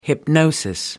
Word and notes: hypnosis: American English

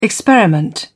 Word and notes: experiment: British English

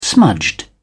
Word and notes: smudged: British English

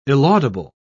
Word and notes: illaudable: British English

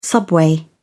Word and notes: subway: British English

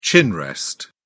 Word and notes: chin rest: British English

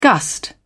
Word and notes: gust: British English

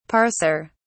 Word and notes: parser: British English

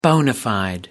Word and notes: bona fide: American English

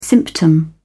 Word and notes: symptom: British English

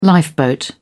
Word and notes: lifeboat: British English

